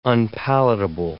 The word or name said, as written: unpalatable